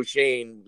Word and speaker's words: Schoen: Schoen is the way to pronounce it.